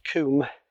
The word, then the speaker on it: combe: So we've got sort of Babbacombe, Maidencombe, spelt C-O-M-B-E, but pronounced Coombe.